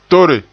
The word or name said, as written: tore